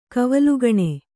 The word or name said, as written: ಕವಲುಗಣೆ